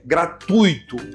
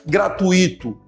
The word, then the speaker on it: gratuito: gratuito, gratuito. Fala a segunda sílaba junto, gratuito, gratuito, nunca gratuito. Isso configura um erro de fonética, de som.